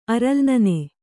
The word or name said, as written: ಅರಲ್ನನೆ